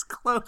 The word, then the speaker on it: clothes: Because the word is clothes. – I know what the word is, but it's got an E in there. You've got to throw that all into one syllable. – Clothes. It's clothes.